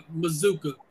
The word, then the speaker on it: Mazuka: By Mazuka, by the way. – Oh, Mazuka. I don't spell his name wrong. My bad. Mazuka. – No, you probably spelled it right. It's just pronounced Mazuka.